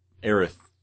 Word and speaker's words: Aerith: It is Ares. Or Aerith. The actual Japanese.